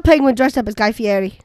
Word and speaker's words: Fieri: I believe it is Fieri.